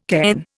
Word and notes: cairn: Indian

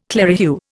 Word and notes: clerihew: Indian

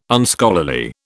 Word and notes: Unscholarly: British English

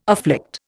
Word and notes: afflict: Indian

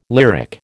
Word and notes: lyric: American English

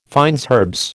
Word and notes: fines herbes: American English

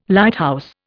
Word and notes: Lighthouse: Indian

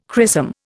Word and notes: chrisom: Indian

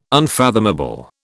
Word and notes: unfathomable: British English

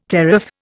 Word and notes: teraph: Indian

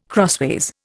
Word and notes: crossways: Indian

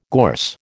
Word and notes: gorse: American English

